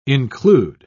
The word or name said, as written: include